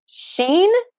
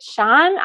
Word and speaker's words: Shein: Some people say it Sheen. Some people say it Shine. I say Shein. That's how I pronounce it.